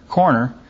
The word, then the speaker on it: corner: You know, I was cursed, blessed to be born in East Texas, and we usually don't pronounce that R in the middle of that word. It's a corner over there.